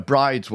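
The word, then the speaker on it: Bryde's: And in all honesty, I'm probably going to get it wrong myself, but everybody calls it a bride's whale, which is fine enough. It's B-R-Y-D-E-S.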